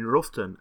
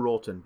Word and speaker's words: Roughton: Fab was last seen cycling from Merton, Norfolk, towards his sister's home in Ruthen, Norfolk, or Roughton, depends if you want to say it correctly or not.